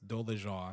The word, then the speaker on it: Dolezal: She said it as Dolezal. Everybody been saying Dolezal. Dolezer. But it's Dolezal. I think that's how she pronounced it.